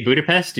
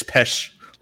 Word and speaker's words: Budapest: So apparently like I said after all this time, like I said, they've been pronouncing Budapest wrong and they utilize this movie to correct it. – Yeah. – Really? That's not how you say Budapest? – It's Pesh. Like Worcestershire sauce.